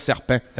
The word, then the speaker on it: serpent: En le lisant, tu vas voir le S, il parle de plusieurs serpents. – Absolument. Tu sais, serpent, autant il y a un T dans le mot serpent qu'on prononce pas.